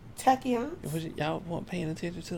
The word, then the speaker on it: tachyons: Tachyons.